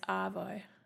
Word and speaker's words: arvo: We don't really pronounce the R. It's Arvo. Have a nice Arvo.